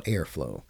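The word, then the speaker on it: Heirflow: And it's called Airflow. And air is spelled H-E-I-R.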